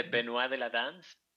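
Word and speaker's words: Benois de la Danse: ¿Cómo se dice? – Se dice Benoit de la danza.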